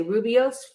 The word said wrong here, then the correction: Rooibos: fan. yeah, Rooibos. – Rooibos.